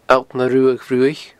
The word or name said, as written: Allt na Ruadh Bhruaich